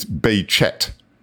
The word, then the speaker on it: Behçet: And if I might pick up on your pronunciation, Geraint, the pronunciation of Halusi Behçet's name, although he's got the cedilla under the C, he's not French, he's Turkish, and it's apparently, I just looked it up yesterday, it's Behçet.